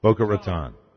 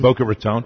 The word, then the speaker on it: Boca Raton: Oh, and then the whole important, it's called Boca Raton. That's a long O in Raton. – Boca Raton.